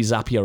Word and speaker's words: Zapier: And then we're really happy, in case you haven't already noticed or heard, that we can announce we now integrate with Zapier. Don't really know how you pronounce it. It's basically Zapier or Zapier. But now if you go into your Zapier or Zapier account, they're both the same thing.